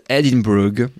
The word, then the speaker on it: Edinburgh: Alors, Glasgow et Edimbourg, que vous prononcerez à l'écossaise Edinburgh.